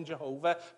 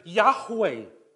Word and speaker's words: Yahweh: call him Jehovah, but they're just spelling it wrong, they're pronouncing it wrong. Because they would, wouldn't they? But Yahweh is gracious.